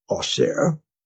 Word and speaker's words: Auxerre: What is Aughshere? A-U-X-E-R-R-E, right? So that is the French pronunciation.